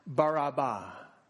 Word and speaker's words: Barabbas: In English, which is taken from the Greek translation, we call him Barabbas. But in Aramaic, the language that they would have been speaking, his name is pronounced Barabbas.